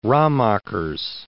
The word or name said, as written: Raemaekers